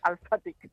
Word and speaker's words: Alphatic: Alphatic, sí, con, de alfabetización Alphatic. – Con PH, o sea que lo pronunciamos como ha empezado hoy el programa mi compañera Andrea diciendo Facebook, pues esto diremos que es Alphatic con H intercalada.